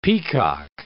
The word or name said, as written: peacock